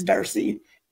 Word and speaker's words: Darcy: It's Bravo Alpha Bravo Uniform Tango Zulu Kilo Echo, and my first name is Darcy.